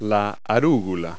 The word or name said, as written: arugula